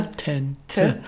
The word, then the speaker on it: Potente: Ähm, was vielleicht noch ganz charmant ist, ihr Nachname Potente. Für uns ist das natürlich klar, wie der ausgesprochen wird. Ähm, hier ist nochmal die Lautsprache für die Amerikaner. – Potente.